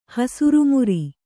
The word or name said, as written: ಹಸಿರುಮುರಿ